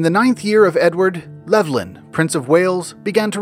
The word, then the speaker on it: Levlyne: I'll also use his version of Llewellyn, which is Levlyn, L-E-V-L-Y-N-E. And I'll pronounce that V as a V. It's possible it could have been a U or a W sound,